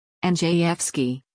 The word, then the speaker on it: Andrzejewski: Andrzejewski, pronounced NG_F_ski, reviewed LA County records and found that the top seven lifeguards made up to $392,000 each in 2019.